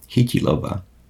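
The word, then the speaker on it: Chytilová: Which is a Czech New Wave film directed by a name I can't pronounce, Vera Chitlova? – Hitilova. It's a soft T.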